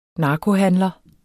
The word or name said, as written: narkohandlere